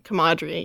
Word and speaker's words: camaraderie: I was about to say, I definitely mispronounced that. – Camaraderie. – Camaraderie. My comrades.